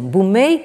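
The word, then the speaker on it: Bumei (文明): Alors, Édith Dufourmont commence par faire ressortir l'intérêt pour Montesquieu d'une société japonaise marquée par l'accès à Bumei, c'est-à-dire la civilisation. Bumei qui est donc la prononciation japonaise de Wenming, dont nous avons vu que c'est devenu le terme chinois pour la civilisation.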